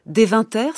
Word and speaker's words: Deventer: Et notamment, en Hollande, il y a une célèbre école des frères de la vie commune à Deventer, c'est comme ça qu'on prononce.